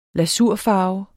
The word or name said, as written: lasurfarve